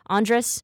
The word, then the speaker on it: András: András, welcome to the program.